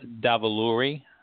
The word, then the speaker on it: Davuluri: There was some key moments leading up to, you know, the announcement of the winner, which was Miss New York, Nina Davaluri. I hope I pronounced her last name yeah. – correctly. – Mm-hmm. – Okay, good.